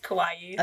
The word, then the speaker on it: kawaii: How do you say it again? Kawaii? Almost like Hawaii. – Yes, exactly. – With a K.